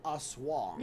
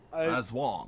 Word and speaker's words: Aswang: We're talking about the broader category, the rectangle in this case, is the, and help me out, I am hearing Aswang. – Yeah, that's it. – Aswang. – Yeah. – That's number one. – Aswang, yeah. – Aswang. – So your accent is on the, on the Wang.